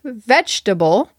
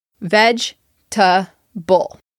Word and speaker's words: vegetable: To me, vegetable has three syllables. Veg-ta-ble. The second E in the word is silent. Students who learned the word through reading and did not hear it spoken would say vegetable as four syllables. The correct pronunciation, once again, is vegetable. Vegetable.